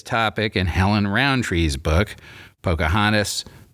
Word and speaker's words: Pocahontas: So here are the ways in which modern Virginians pronounce the names of the main protagonists in the book: Powhatan, Pocahontas, Opechancanough."